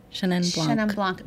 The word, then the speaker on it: Chenin Blanc: Chenin Blanc.